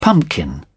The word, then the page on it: pumpkin: British English